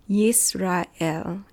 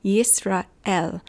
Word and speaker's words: Yisrael: Yisrael is how you would pronounce it in Hebrew. I'm exaggerating it, but Yisrael.